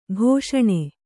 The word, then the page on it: ಕಷಣ: kaṣaṇa